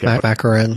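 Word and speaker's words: McMoRan: And kind of the same idea when you look at copper, Freeport McLaren. – I think Macaron. – Yeah, thank you. I butchered that.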